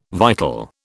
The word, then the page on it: vital: British English